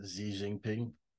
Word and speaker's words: Xi Jinping: The Chinese president, how do you say his name? – Xi Jinping.